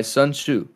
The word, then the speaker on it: Sun Tzu: Ronan asks, a while back, Fruit said he was reading The Art of War by Sun Tzu.